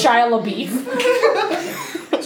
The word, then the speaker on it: Shia LaBeouf: It's pronounced Shia LaBeouf.